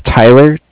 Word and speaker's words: Tyler: Okay, my name is Tyler, T-Y-L-E-R. – Okay. – And I'm calling with Cardiff. It's spelled C-A-R-D-I-F-F, as in Frank Frank.